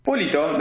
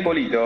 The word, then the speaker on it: Polito: Polito. Daniel Polito. Polito. Es un apellido italiano, así que, pero sencillo, fácil. Polito directamente.